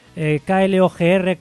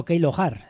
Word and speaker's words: KLOGR: Y los que vais a escuchar a continuación, KLOGR, Klogr, pero no se pronuncia así. Realmente la pronunciación, según apuntan por aquí, sería más o menos la siguiente, una banda italo-suiza que vendría a ser algo así como Keilohar,